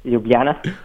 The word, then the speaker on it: Ljubljana: Uh, Ljubljana.